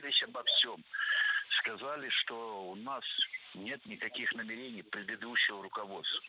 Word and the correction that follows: намерений: Намерения, это неверно. Правильно, намерений. Ударение в этом слове ставим на второй слог и не меняем это ударение при изменении слова. Намерению.